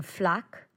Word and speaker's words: Flacq: there's an open air market in Flac. Flac. Or maybe it is Flic on Flac. I don't know. It's Flac. Whatever. It's in the east. No. It's Flac. F-L-A-C-Q is how it's spelled. Okay. And it's on the east of the island. And so F-L-A-C-Q, Flac.